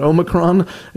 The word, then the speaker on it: Omicron: Now that we've resolved how to pronounce it, we're calling it Omicron.